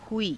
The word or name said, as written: huii